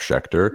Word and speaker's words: Sheckter: you pronounce my name right it's jeff sheckter yes um,